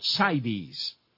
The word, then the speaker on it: CITES: CITES. Did I pronounce it properly, and what is that? – You did.